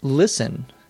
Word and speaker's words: listen: Why don't we pronounce the T in listen? Why isn't it not listen? Well, there is no easy answer to that question. You just have to realize that many words in English have what we may call a silent T. For example, C-A-S-T-L-E is castle, not castle.